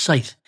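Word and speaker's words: seiðr: Some heathens are also rediscovering northern European shamanic practices known as saith, pronounced saith.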